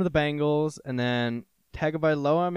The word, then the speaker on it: Tagovailoa: A, Tua Tagovailoa.